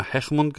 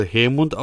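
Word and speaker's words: Heahmund: Ich habe gestern immer Hechmund gesagt, aber er wird wohl Hermund ausgesprochen.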